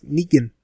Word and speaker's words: Negan: And now we finally know how to say it. It's Negan. – Yep, Negan it is.